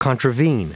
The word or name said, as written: contravene